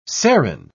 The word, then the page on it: serin: British English